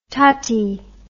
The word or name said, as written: tati